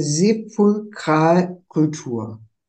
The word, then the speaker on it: Sepulkralkultur: Sie sind der Direktor, kommt jetzt ein schwieriges Wort, der Direktor des Zentralinstituts und des Museums für Sepulkralkultur. Richtig ausgesprochen? – Das stimmt.